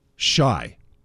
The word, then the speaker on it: Shay: I always say shy instead of Shea. Mm-hmm. You know why that is? – No. – One of my favorite restaurants in the city is spelt S-H-A-Y, but it's pronounced Shy.